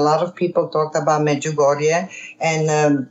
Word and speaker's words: Medjugorje: Visions of Mediocre. You help me, please. – Medjugorje. Visions of Medjugorje.